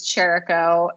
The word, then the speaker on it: Chirico: So my last name is Chirico, and it is spelled C-H-I-R-I-C-O, and that seems to give a lot of people trouble.